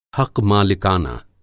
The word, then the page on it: हक़-मालिकाना: Hindi